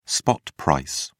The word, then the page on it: spot price: British English